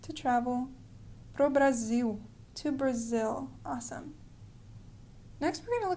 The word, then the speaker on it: Brasil: And remember in Portuguese, Brazil is spelled with an S, not a Z. So we say Brazil, but they say Brazil.